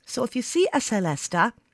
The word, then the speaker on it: celesta: It's called the celesta, which can also be pronounced celesta, if you like. But celesta is a very common way to say it. It comes from the French word céleste, meaning heavenly.